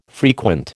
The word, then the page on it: frequent: American English